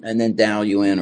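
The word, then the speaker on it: Daoyuan: and then Dao Yuan or which is the Chinese pronunciation for Dogen,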